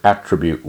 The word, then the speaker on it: attribute: He pronounced the word attribute with great relish, laying the accent on the first syllable.